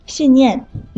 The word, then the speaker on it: 信念: 而且还要提醒大家，信念两个字都是四声，需要注意不要把声调说错。